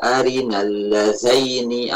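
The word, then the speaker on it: أَرِنَا ٱلَّذَيْنِ: Rabbana arina allazaini adallana, azallaini, allazaini, arina allazaini, bukan arina allazina.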